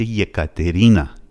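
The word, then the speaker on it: یکاترینا (Екатерина): مثلا شما اگه کسی اسمش کاترین باشه، اسم واقعیش میشه یکاترینا، یکاترینا، یکاترینا یه هستش اولش، حرف ای انگلیسی یه، ولی یه تلفظ میشه، یه ایکراتکایه نیستش، میشه اونم نوشت ولی غلطه.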